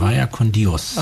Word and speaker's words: Vaya con Dios: Und für mich war da eigentlich sofort auch ein Lied da von Vaya con Dios, so werden die ausgesprochen? – Vaya con Dios.